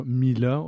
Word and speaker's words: Mlah: Ça se prononce mal, c'est M-L-A-H, mieux connu sur Mila et non Mla.